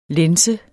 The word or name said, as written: lænse